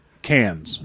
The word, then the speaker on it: Cairns: And Melissa has said that we want to take a couple of weeks off and go on a real family vacation to Cairns.